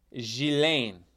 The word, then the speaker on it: Ghislaine: It's Ghislaine. – Ghislaine. – No, the S isn't pronounced. They haven't been pronouncing the S.